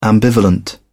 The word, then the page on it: ambivalent: British English